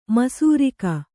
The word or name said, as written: ಮಿಸಿರಿಕೆ